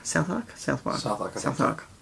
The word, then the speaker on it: Southwark: Southwark. – You don't pronounce the W.